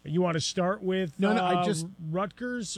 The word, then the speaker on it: Rutgers: Remember, it's Rutgers. Rutgers. I told you that's how you say it. – Yeah, there's no Rutgers. – T.